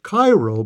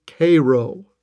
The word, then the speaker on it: Cairo: Many people not familiar with Illinois or the Civil War would pronounce it Cairo because it is spelled C-A-I-R-O like Cairo, Egypt. But the locals and Civil War buffs know it is Cairo.